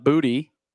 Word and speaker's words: Booty: LSU wide receiver Kayshon Booty. – Booty? I think it's Booty. – I've actually heard the official pronunciation that he prefers is booty.